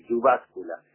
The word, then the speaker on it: Jyväskylä: los finlandeses pronuncian aproximadamente, como lo voy a decir, Jyväskylä, una pequeña o mediana ciudad que tiene una obra soberbia de uno de los grandes arquitectos del siglo XX, ¿no?